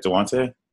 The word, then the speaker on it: Dejounte: Getting rid of Tony Parker, giving the keys to DeJounte Murray. Or is that how you say it? DeJounte? – DeJounte.